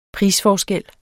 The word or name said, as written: prisforskel